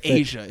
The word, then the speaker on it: Aja: And like you got fucking Aja on 180 degree vinyl. Exactly. – 180 gram. – Asia, it's pronounced Asia.